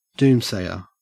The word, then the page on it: doomsayer: Australian English